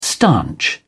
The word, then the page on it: stanch: British English